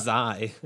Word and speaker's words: Xi: Also, it's not Chi. – It is Chi. – No, you were also in a fraternity and had to know this. It's Xi.